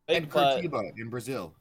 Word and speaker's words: Curitiba: Yeah, Curitiba is how you pronounce that.